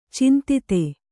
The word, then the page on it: ಚಿಂತಿತ: cintita